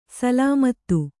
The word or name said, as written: ಸಲಾಮತು